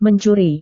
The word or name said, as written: mencuri